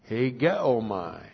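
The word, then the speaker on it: Hegeomai (ἡγέομαι): Hegeomai. H long E G E O M A I.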